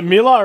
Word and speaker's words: Mila Kunis: She was like, you both butchered it, but that's okay. – It's Mila. – Mila. Mila. Mila. – Mila Kunis. – It's Mila, right? – Got it right, yeah.